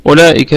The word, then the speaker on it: أولئك: الآية الأخيرة من هذه الصفحة، أولئك، أولئك الهمزة همزة الأصلية تثبت في البدء والوصل، وهذه الواو لا تلفظ لا تنطق، يعني نراعي هذه المسألة، هذه الواو التي عليها الصفر البيضاوي لا تلفظ، يعني سنكتفي بالضمة التي على الهمزة، أولى أولى أولى أولئك، تمام.